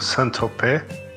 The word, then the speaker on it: Saint-Tropez: É fazer uma correção aí, boi, desculpa aí te corrigir é, a pronúncia, digamos assim, corre correta, ou pelo menos próximo seria algo como Saint-Tropez.